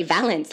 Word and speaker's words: Valance: Is that how you say it? Valance? – Is it Valance? Or was it Valanche? – Oh, I was going to say Valance. Like the thing.